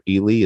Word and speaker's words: Ehle: And a wonderful, wonderful performance, as you'd expect from Jennifer Ely. Is that how it's pronounced? I don't know. – Yes. – E-H-L-E. – Mm-hmm. – Is that how it's pronounced? – Mm-hmm. Yeah.